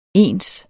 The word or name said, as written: ens